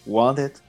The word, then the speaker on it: Wanted: É, One the Dead or Alive, ou desculpa a pronúncia errada aí, Wanted, que eu costumo falar, é uma música country raiz, o qual o Bon Jovi traz a gente para aquela atmosfera do faroeste.